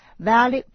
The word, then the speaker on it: valet: 我们往下听 Tate 女士还出了什么好主意，但是先得学个短语 valet parking. Valet parking. Valet is spelled V-A-L-E-T.